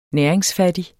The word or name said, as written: næringsfattig